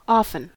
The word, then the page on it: often: American English